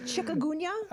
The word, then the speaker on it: chikungunya: How do you pronounce it? Chikungunya? – Chikungunya.